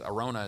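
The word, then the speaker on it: Araunah: His name was Arauna, I think is Arona is how you pronounce it.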